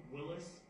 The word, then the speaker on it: Willis: Lieutenant Tim Willis. That's W-I-L-L-I-S, the Oakland County Sheriff's Office.